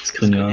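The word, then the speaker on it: Škriniar: Ich kann ihn nicht aussprechen, äh, von Milan, äh, nicht ob von Mailand, äh, Milan Skriniar. – Skriniar, Skriniar ja.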